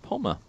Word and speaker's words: Pommer: And that's Marcus Palmer. – Marcus Apple? – It's spelled the same way. P-O-M-M-E-R.